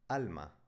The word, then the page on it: Alma: spanische